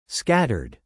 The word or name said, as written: scattered